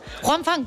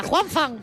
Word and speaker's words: Wang Fan: Juan Fan, que me encanta, Juan Fan. Podemos llamarlo así, se escribe con H, pero la vamos a llamar Juan Fan.